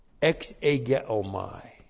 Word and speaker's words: exegeomai: Ex egeomai. That's E-K-S long E-G-E-O-M-A-I.